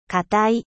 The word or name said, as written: dur